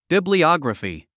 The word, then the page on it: bibliography: bìbliάɡrəfi; ビブリアグラフィ